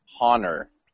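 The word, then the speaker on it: Hohner: The main one that was like the interesting I believe it's pronounced Honer. – Honer. It's Honer.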